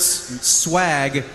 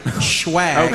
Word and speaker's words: swag: You don't pronounce swag, schwag.